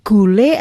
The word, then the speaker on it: gula: itu rambut nenek itu kalau di Lombok, tapi ini gule atau gula itu Mas? – Kalau tulisannya gula, kalau bahasa sana kan bacanya gule. – Gule. – Seperti Pantai Kuta, bacanya Kute.